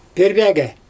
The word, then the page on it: pIrbAgA: Xyzyl